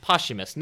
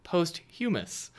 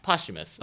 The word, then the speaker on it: posthumous: For those who don't know, it is pronounced posthumous. Nick isn't sounding ridiculous. He's pronouncing the word right. When you look at it, you might think posthumous, but it's not. It's posthumous.